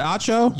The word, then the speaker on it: Acho: Am I saying it right? Yes. I, is it Acho? Yes.